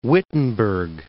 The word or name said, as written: Wittenberg